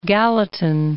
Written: Gallatin

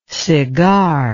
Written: segar

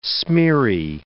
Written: smeary

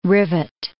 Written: rivet

